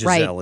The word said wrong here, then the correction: Ghislaine: right. – It's Ghislaine. Yeah.